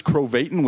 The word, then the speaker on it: Krovatin: Chris Gro- Krovatin, which is Chris Krovatin, which is pro Satan. That's what it rhymes with.